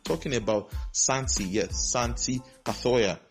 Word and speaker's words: Cazorla: It's Santi Cazorla, not Cazorla. Yeah, it can be hard to pronounce Cazorla sometimes because it's the Spanish custom of naming. But actually, it is Cazorla, not Cazorla. Not the way it is spelled, not Cazorla. It's Cazorla. Then another one, Memphis.